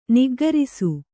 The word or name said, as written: ನಿಗ್ಗರಿಸು